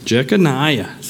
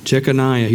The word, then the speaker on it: Jeconiah: Jeconiah. Sounds good to me, brother. That's awesome. Jeconiah.